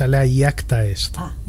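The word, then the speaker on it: alea iacta est: La pronunciación correcta es alea jacta est.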